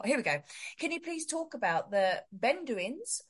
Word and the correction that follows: Bedouins: And Bedouins.